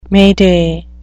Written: mayday

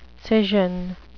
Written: scission

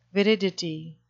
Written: viridity

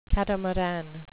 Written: catamaran

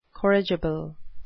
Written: corrigible

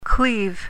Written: cleave

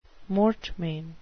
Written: mortmain

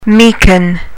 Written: meeken

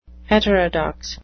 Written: heterodox

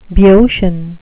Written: boeotian

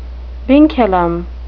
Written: vinculum

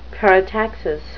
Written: parataxis

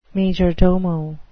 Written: majordomo

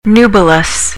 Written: nubilous

